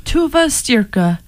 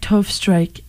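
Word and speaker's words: Tove Styrke: It's pronounced Tuva Stierka, but I don't mind Tove Strike. It's all right. – Granted, I didn't say Tove Strike because I can see that the Y comes before the R, but I still didn't do my homework.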